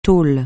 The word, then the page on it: taule: francais